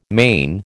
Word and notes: mane: American English

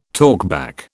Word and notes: talkback: British English

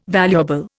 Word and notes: valuable: Indian

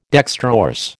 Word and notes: dextrorse: American English

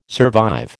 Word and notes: survive: American English

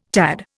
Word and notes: tad: Indian

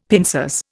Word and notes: pincers: Indian

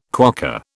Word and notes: quokka: British English

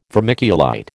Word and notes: vermiculite: American English

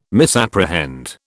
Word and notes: misapprehend: British English

